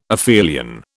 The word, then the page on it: aphelion: British English